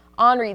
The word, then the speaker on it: Henri: Henry, right? – Yeah, that's what I said. – No. – I said Henry, and he goes, no, Sloan, it's Henri. So that's how this storm is pronounced.